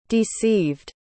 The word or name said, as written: deceived